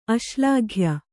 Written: ಅಶ್ಲಾಘ್ಯ

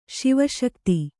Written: ಶಿವಶಕ್ತಿ

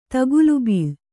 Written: ತಗುಲುಬೀಳು

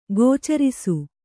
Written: ಗೋಚರಿಸು

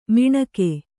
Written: ಮಿಣಕೆ

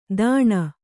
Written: ದಾಣ

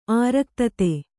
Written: ಆರಕ್ತತೆ

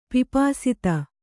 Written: ಪಿಪಾಸಿತೆ

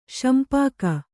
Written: ಶಿಂಪಿಗ

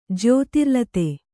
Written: ಜ್ಯೋತಿರ್ಲತೆ

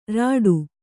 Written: ರೀಠೆ

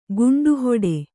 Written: ಗಂಟುಹೊಡೆ

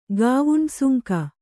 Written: ಗಾವುಂಡಸುಂಕ

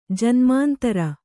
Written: ಜನ್ಮಾಂತರ

